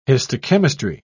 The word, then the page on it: histochemistry: British English